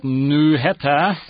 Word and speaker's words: Snøhetta: Snøhetta.